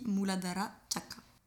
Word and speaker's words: Muladhara Chakra: Donc voilà pourquoi on attaque par ce chakra, le chakra racine que l'on prononce en sanskrit, Muladhara Chakra.